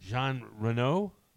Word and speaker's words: Jean Reno: Jean Reno? – Yep.